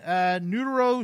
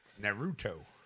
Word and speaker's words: Naruto: Neuro Shippuden Ultimate Ninja Storm Legacy by Bandai Namco Entertainment America Incorporated for the PS4 and Xbox One. And that's a Friday release. – Naruto.